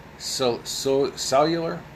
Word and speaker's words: Cuellar: In fact, the border crisis compounded with the pandemic has worsened to the point Democrat Henry Cellular, C-U-E-L-L-A-R, Cellular, I'll pronounce it Cellular, screw it,